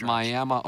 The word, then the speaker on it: Miami: They pronounce it Miami, Oklahoma. Did you know that? That's from Wolf Boy. – I didn't know that. Miami.